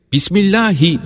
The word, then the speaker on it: Bismillahi: Bismillahi derken H'yi belli etmek lazımdır.